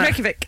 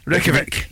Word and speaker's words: Reykjavik: But it is Reykjavik, isn't it? – Reykjavik. – Reykjavik. Reykjavik. Right. Reykjavik. How do you spell it, though? Have a think. Go. – R-E-Y-K-J-A-V-I-K.